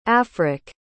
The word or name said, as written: afric